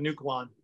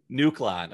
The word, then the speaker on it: Nuclon: How do you pronounce that, by the way? – We just always said Nuclon. – Nuclon. Oh, like a nuke.